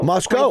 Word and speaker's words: Moscow: Moscow. Locals. – That's how we pronounce. That's how we pronounce it here.